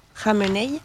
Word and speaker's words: Khamenei: Et donc, la réponse iranienne, Ali Khamenei, j'essaye de ne pas le dire de la même manière que Khomeini, mais ça s'écrit bref, Khamenei, celui qui est l'actuel gardien de la révolution, etc., a fait des déclarations déclarant que c'est une déclaration de guerre.